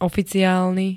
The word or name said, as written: oficiálny